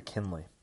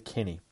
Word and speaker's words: Kinney: And so I've just realised I've spent my whole life calling her Kinley. Never noticed it was actually Kinney. Oh well, you get that. You learn something new every day here on the Comic BS. Today we learned how to pronounce Laura's last name.